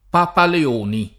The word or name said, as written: Papaleoni